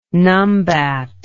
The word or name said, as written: numbat